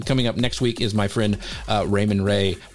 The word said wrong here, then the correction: Ramon Ray: Ramon Ray. I say his name wrong all the time.